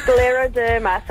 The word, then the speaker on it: scleroderma: Say it again. I'm writing it down. – Scleroderma. So it's scleroderma. – Sclero. – Okay, now we know how to say it.